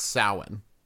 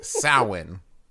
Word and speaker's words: Samhain: Yeah, it's Gaelic, so it is not at all pronounced the way it looks. Samhain.